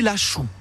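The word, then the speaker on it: Laxou: De l'Axou, comme on disait autrefois. Alors j'allais vous poser la question, il paraît que dans le temps, ça s'appelait pas l'Axou, enfin, fallait le prononcer l'Achou.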